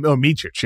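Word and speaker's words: Micić: The how do you pronounce the new point guard? I've only seen Micic. – Micic, yes. Yep.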